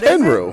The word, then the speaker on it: Tenryu: Tendu. – Yeah, Mr. Tendu. – Tendu. – Tenryu Tendu? – No, his last name is Tenryu, but Bobby Heenan called him Tendu for Tendu.